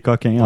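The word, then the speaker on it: Cokin: Mais on prononce Kawkin ici, mais c'est Coquin en fait.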